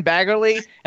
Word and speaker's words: Baggerly: You guys Google Glen Baggerly and then hit images. You can see all kinds of pictures. The guy's totally ripped. And how do you pronounce is your name Baggerly or Baggerly? – Baggerly.